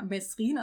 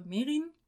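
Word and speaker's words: Mesrine: Also nicht nur wir haben das Problem, sondern im Film sagt die Hälfte der Leute Mesrin, also mit S, und die andere Merin. Aber er korrigiert die Leute, die es falsch aussprechen, in zwei Fällen.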